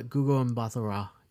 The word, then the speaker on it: Gugu Mbatha-Raw: It stars, I am going to butcher her name, but it's Gugu Mbatha-Raw. – Gugu Mbatha-Raw.